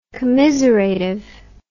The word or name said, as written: commiseratively